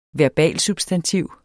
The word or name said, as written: verbalsubstantiv